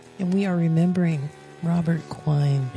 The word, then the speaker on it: Quine: Quine? – Yep.